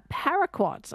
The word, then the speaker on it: paraquat: We are going to talk, though, about a herbicide, paraquat. I think you can correct me if the pronunciation's incorrect. – No, you've got it absolutely right there.